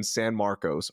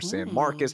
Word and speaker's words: San Marcos: You got to go to Sun's Blue River Camp in San Marcos or San Marcos, however you want to pronounce it.